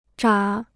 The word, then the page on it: 眨: zhǎ